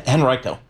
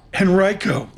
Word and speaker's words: Henrico: Henrico. – Henrico. – Henrico. – Henrico.